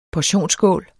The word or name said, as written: portion